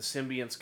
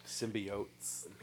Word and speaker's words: symbiotes: The first one, while they were dealing with trying to, you know, the symbionts coming to Earth and trying to discover what they are. – Symbiotes.